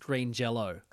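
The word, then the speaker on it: Green Jellÿ: But they say, the band, the umlaut over the Y makes it an O sound. So they're still pronounced Green Jello.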